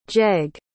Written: gegg

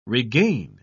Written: regain

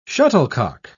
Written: shuttlecock

